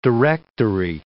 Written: directory

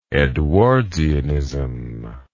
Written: edwardsianism

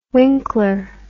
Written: winkler

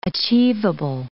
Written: achievable

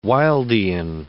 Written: Wildean

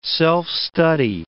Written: self-study

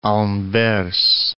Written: Anvers

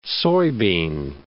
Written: soybean

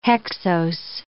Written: hexose

